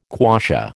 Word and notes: quassia: American English

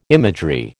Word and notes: imagery: American English

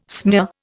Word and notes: smear: Indian

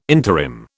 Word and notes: interim: British English

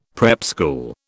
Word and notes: prep school: British English

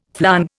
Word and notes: flong: Indian

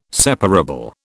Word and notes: separable: British English

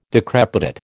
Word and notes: decrepitate: American English

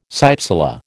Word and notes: cypsela: American English

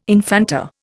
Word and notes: infanta: Indian

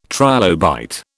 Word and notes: trilobite: British English

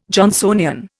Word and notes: Johnsonian: Indian

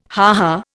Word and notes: ha ha: Indian

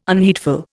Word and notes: unheedful: Indian

